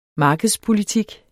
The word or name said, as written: markedspolitik